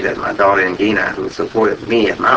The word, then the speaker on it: Ngina: Ingina, N-G-I-N-A, pronounced Ingina.